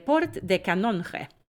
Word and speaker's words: Port de Canonge: Men om jag ska, om jag ska gå till hur det brukar vara i alla fall så ska det här uttalas eh, Port de Canonge.